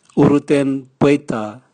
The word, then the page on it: urutenbweta: Raga